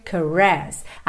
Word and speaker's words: caress: Desperate caress. Atenção aqui às sílabas tônicas. Desperate é a primeira, caress é a segunda, ok?